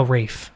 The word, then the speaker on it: Ralph: because I know for a fact it's spelled Ralph, but I believe he pronounces it Rafe or Rife. No, Rafe. Yeah. Like Ray F.